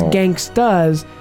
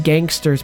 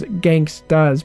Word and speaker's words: Gangsta's: Gangsta's Paradise, and it is Gangsta's, and you have to say it like that because on Jeopardy that was the answer to one of the questions. – Ooh. – And he said Gangster's Paradise and it was wrong. It was Gangsta's Paradise.